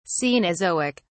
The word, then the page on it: cenozoic: British English